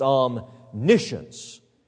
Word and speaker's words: omniscience: I've hyphenated the word omni meaning all, science meaning to know. It is pronounced omniscience.